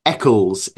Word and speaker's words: Eccles: Eccles.